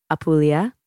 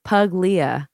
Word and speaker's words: Puglia: Some people call it Apulia, but Puglia is spelled P-U-G-L-I-A. So Puglia, but if you're going to pronounce it correctly, it's Puglia, kind of rolls off the tongue in a really romantic way.